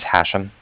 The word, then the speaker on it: Hashem: So, my name is Hashem. That's okay. H-A-S-H-E-M. And I was calling from Cardiff. That's C-A-R-D-I-F-F, like Frank.